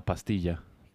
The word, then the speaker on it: pastilla: Pastilla. – That's weird. – La pastilla. – Pastilla? – Yeah. – Huh. Tortilla? – Yes, exactly. Tortilla. That's how you say pill.